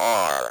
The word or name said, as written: are